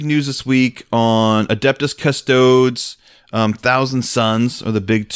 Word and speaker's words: Custodes: You're supposed to say Custodes, that's what I've been told. Like, you have to pronounce every vowel.